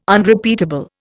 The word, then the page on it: Unrepeatable: Indian